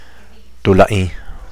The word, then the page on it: tulaing: Teotitlán del Valle Zapotec